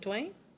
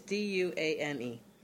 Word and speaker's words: Duane: Is it Duane? – I think so. – It's Duane. – Yeah, if I was told that pronounced Duane, it's a first name, usually, but.